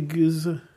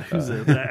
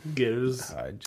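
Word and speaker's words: gueuze: How do you say goose? – That's closer than... – it's not Huzza. I made sure to pronounce the G this time, because now it's seared in my memory that we were wrong.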